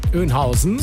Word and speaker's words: Oeynhausen: Kreuz Bad Oeynhausen. Auch so? – Oeynhausen, ja.